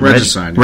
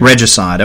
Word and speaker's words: Regicide: is it Regicide? Am I saying that right? – Regicide. – Regicide, okay.